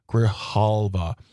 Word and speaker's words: Grijalva: It's a Democrat, a democratically elected woman named Adelita Grijalva. Sorry, I pronounced that incorrectly. Grijalva.